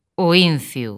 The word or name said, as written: A Cruz do Incio